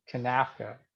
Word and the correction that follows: CONCACAF: It's CONCACAF, right? – You got it. Nailed it.